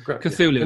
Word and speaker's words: Cthulhu: Cthulhu, yeah. – That's how you pronounce it. – Cthulhu.